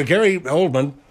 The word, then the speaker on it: Gary Oldman: It's Gary Oldman.